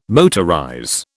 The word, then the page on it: motorize: British English